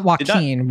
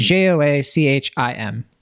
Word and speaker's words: Joachim: Not Joaquin. Joachim. I don't know Joachim. If I'm pronouncing that right. It's Joachim.